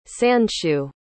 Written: sandshoe

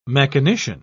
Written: mechanician

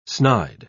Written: snide

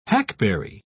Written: hackberry

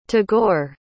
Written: tagore

